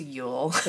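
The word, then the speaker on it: Jól: Oh, so J-O with the little K on top of it and the L, that's pronounced Yule.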